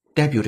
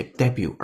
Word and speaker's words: debut: 首先，我们来注意听一下这个句子当中 debut 这个单词的发音，因为是英音，它把重音放在第一个音节 debut，而美音当中喜欢把重音放在第二个音节 debut。